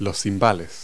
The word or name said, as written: cymbals